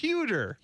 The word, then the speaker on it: Huerter: Is that how you say it? Huerter? Huerter? – How's it spelled? – H-U-E-R-T-E-R. Huerter? – Huerter. – Huerter. – What did I say? Not Kevin Huerter.